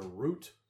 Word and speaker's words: route: The word route can be pronounced either root or route in the U.S. – Dealer's choice. – It's dealer's choice.